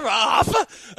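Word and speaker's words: Rafa: Like, oh, he's like, he tells me, Hafa. I'm like, oh, that's how you say his name, Hafa?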